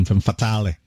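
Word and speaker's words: Femmes Fatales: Well, as you know, in French you don't pronounce the S, so that's why I pronounce it exactly the same way. Femme Fatale and then Femme Fatale.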